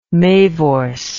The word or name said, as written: mavors